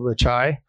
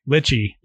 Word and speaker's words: Lichai: So that one's called Lichai. Lichai is the Navajo word for red. And so it's I'm glad you pronounced that, because I look at that and I'm like, lychee.